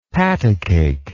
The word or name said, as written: patacake